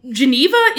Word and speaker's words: Geneva: Geneva is correct in English. I'm just pronouncing it Geneva because je suis francophone. Both are correct, and if anyone tells you different, they're just being a pedantic asshole.